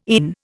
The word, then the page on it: ENE: Indian